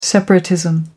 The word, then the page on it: separatism: American English